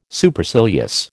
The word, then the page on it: Supercilious: American English